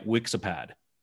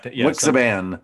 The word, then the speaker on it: Wixiban: And now, when I first heard Neelix say his name, it sounded like Wixipad. Like there's an iPad. – Wixiban. – Wixipad. I heard Wixiban. A PAD at the end.